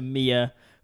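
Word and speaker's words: Mie: Here in Denmark, everybody, you pronounce the letter E like the letter A, and so her name, which is spelled M-I-E, I thought was my. It's Mia. Yeah.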